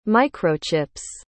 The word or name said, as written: microchips